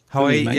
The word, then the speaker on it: Huawei: I don't know how you pronounce it. Is it Huawei? – Huawei, yeah, the P20.